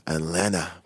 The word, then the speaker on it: Atlanta: J'adore ne pas prononcer le T de Atlanta comme les Américains. Atlanta. C'est comme Toronto, tu sais.